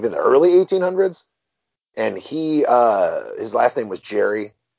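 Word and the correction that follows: Gerry: It was actually Gary. – Gary, was it pronounced Gary? I'm sorry, I just read it. – No, it's actually one of those things, where we should be saying gerrymandering all this time.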